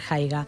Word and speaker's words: haiga: Es diu, bueno, s'escriu a mag, però bueno, es pronuncia Haiga.